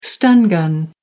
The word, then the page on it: stun gun: American English